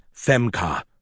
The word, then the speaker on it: Femke: Hank's wife, Femke, yes, that is her real name, pronounced Femka, no doubt took his side and fueled the fuck your ape like father fire.